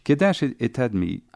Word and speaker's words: Kedash Etadmi: And it, you pronounce that Kedash? – Kedash Etadmi, okay. Which is really a transcription of the Arabic.